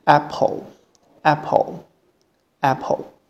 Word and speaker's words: apple: 但是如果是在单词中间或结尾的时候，一般发音是 o，所以在这里发的音是 o，所以连在一起就是 apple，apple，apple。